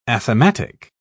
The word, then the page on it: athematic: British English